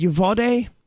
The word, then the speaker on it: Uvalde: And it's unclear, the last I checked, what the, you said Uvalde is how it's pronounced, what his motives actually were, other than, you know, the same old story about this bullied kid.